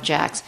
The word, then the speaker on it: Jacques: So he hired, I don't know if the guy, his name is actually Jack, which is even funnier. His name is, but it's like Jacques. He's Canadian, so it's the Jacques of Jacks. But Jacques, but pronounced Jack.